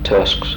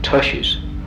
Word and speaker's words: tusks: Now, I have heard some people mispronounce it and call them tushies. – Yeah. – Instead of tusks.